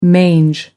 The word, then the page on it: mange: American English